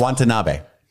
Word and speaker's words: Watanabe: When you finally pronounce his name correctly, he's going to give you a hug very tearfully and say, beautiful. – Watanabe. – There you go.